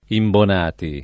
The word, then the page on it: Imbonati: italiana